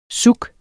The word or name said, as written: zouk